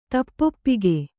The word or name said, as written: ತಪ್ಪೊಪ್ಪಿಗೆ